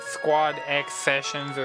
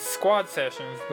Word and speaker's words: Squad X Sessions: Like, we can't call it Squad X Sessions or Squad Cross Sessions. It's Squad Sessions, but just the X is silent.